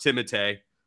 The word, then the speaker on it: Timothée: Timothée Chalamet, and it is pronounced Timothée. I know it sounds pretentious as hell to say his name correctly, but that's actually is how that really how it's pronounced?